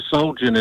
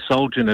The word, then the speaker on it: Soljan: not really, Simon, because Soljan, it's anglicised, so Soljan is the easy English pronunciation. Croatian pronunciation is Soljan. But call me Soljan, it's easier for you, get your tongue around.